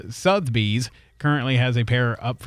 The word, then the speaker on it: Sotheby's: It's Sotheby's.